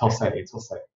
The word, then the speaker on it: Jose: Like, he was going to mispronounce my name, and I'm going to like, it's Jose.